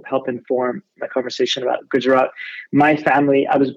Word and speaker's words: Gujarat: Gujarat, yeah, yeah.